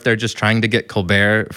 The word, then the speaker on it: ColBERT: No, I'm not totally sure, but according to their website, it's supposed to be pronounced ColBERT, which is the name of some comedian on TV in the US.